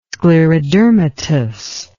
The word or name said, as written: sclerodermatous